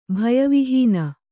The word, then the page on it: ಭಯವಿಹೀನ: bhaya vihīna